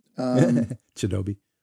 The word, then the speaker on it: Chidobe: Chidobe.